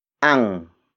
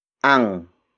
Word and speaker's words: Ang: The next word is ang. Ang. Ang. Ang is spelled as A-N-G.